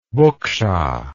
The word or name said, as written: buqsha